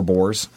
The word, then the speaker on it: bores: It's B-O-R-E-S, like Boris. – B-O-R-E-S. – Your motorcycle has four Boris. – Oh, Boris, got it. – Yeah, but it's Boris, pronounced Boris.